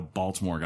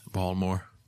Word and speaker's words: Baltimore: Like, I remember when Maryland won the national championship in basketball and how Baltimore got torn apart from it. So, you didn't say that right. – Baltimore.